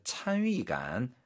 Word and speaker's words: 参与感: 공조어다 그 일하는 데 있어서의 찬위감. 이때 발음 유의, 찬위 사성입니다.